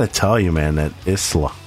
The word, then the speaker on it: Isla: It's Isla. Yeah, the S is silent.